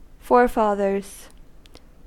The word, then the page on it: forefathers: American English